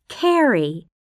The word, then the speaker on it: Kerry: It's D-E-N-A. – 3. – Hello, my name is Carrie. – Kelly? – No, it's Carrie. – How do you spell that? – K-E-R-R-Y.